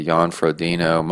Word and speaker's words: Jan Frodeno: Jan Frodeno.